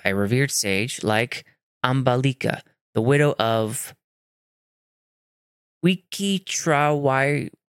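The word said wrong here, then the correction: Vichitravirya: I'm so sorry if I butchered that. Let's take a look. See if we can get that pronounced. – Wichitrawirya. Wichitrawirya. – Wichitrawirya. That one's hard to pronounce.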